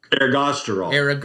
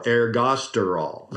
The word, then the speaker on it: ergosterol: There's interesting stuff with, I can't say it properly, ergosterol? – Ergosterol. Ergosterol.